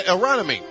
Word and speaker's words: Aronimink: You mean Aronimink? Aronimink. I believe I learned how to say it correctly.